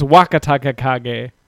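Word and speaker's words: Wakatakakage: Amber, that's not even, well, that is kind of close to how you spell it, but it's Wakataka Kage. So Wakataka Kage.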